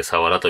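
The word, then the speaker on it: 鰆: それから、えー、他にもサワラというのがおすすめですね。魚平に春と書いてサワラと読みますけど、このお魚なかなか美味しいです。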